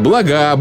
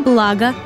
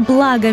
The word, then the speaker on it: блага: Запомните, ребята, в формах множественного числа существительных «благо», «блюдо», «средство» ударение не ставится на последний слог. Так произношение «блага», «блюда», «средства» является грубейшей ошибкой и свидетельствует о низкой культуре говорящего. – Правильно же следует произносить эти слова с ударением на первый слог. «Все блага», «ни за какие блага мира», «красивое блюдо», «вторые блюда», «выделенные средства».